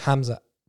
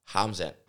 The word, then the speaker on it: Khamzat: I believe in Russia you say Hamzat, and other places you'll pronounce the K.